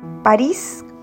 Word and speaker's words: París: París como si tuviera acento en la I. Así lo dice el mismo ministro cuando le preguntan el cómo se pronuncia su apellido.